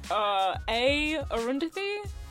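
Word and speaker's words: Aarundhathi: You can find me at A. Arundhati.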